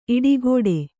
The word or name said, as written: ಈಡುಗೋಡೆ